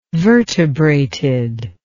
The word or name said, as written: vertebrated